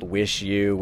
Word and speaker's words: WIXIW: The new Liars record is called Wish You, which is spelled WIXIW.